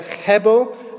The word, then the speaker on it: hebel: Now that word futility in the Hebrew is the word hebel. If you say it right, you spit all over the person you're talking to.